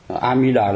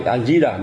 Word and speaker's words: A Di Đà: Amida là sai, a di đà mới đúng.